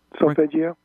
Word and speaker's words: Solfeggio: One thing I've seen references to, and I don't know much about it, but I'd love to hear your answers, is the Solfeggio scale. Did I pronounce that correct? – Solfeggio. – Solfeggio, yeah.